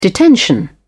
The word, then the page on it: detention: British English